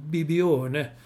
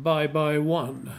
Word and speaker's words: Bibione: Men den uttalade engelsmännen som bye bye one. Bibione, bye bye one. Å andra sidan så uttalade tyskarna som Pippione. För de har svårt med mjuka och hårda B och P.